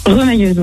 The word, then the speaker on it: remailleuse: On dit, ça se prononce pas comme ça? Remailleuse? – Remailleuse, oui. – Ah, remailleuse, pardon. – Ok, Eratum pour les mailles du coup, rien à voir.